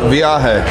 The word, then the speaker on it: Viaje: Well, I'll look it online and get the name correct, but trying to figure out how to pronounce it is Avaje. – What? Viaje. – Viaje. – V-I-A-J-E.